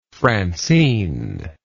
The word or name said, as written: francine